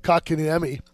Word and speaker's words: Kotkaniemi: Kotkaniemi. – Kotkaniemi.